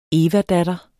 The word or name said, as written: evadatter